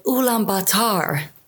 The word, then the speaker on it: Ulaanbaatar: First of all, the name of the capital, Ulaanbaatar.